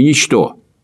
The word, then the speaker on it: ничто: Не затруднит ли вашего гостя объяснить разницу между словами «нечто» – ударение на первый слог – и «ничто» – ударение на второй слог?